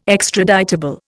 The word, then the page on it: extraditable: Indian